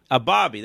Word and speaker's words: Abebe: How do you pronounce this? Is it a baby, a BB? – A Bobby.